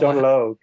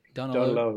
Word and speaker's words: Donal Og: Is it, how do you pronounce it? Donal Og or Young Donal? – Donal Og. – Donal Og. – Og, yeah. Gotcha.